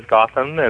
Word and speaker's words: Gautam: I did change the spelling, although never officially, to G-O-T-H-A-M, just because I used to work in television a lot and nobody could ever pronounce my name, and I've always pronounced it as Gautam,